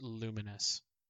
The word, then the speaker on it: Lumines: So it's confirmed to be pronounced Luminous. – Luminous, nice, makes sense to me.